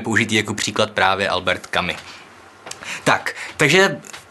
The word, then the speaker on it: Camus: Eh, Hned hnedka na začátek ujasním, hledal jsem si to a skutečně se to vyslovuje kami v prvním pádě, ale kamise ve druhém pádě.